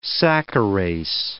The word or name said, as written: saccharase